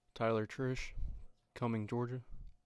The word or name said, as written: Tyler Triche